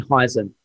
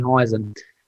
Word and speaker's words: Hsieh: Heisen. His name is spelled H-S-I-E-H, Tony Heisen.